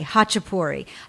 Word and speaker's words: Khachapuri: Okay, Khachapuri.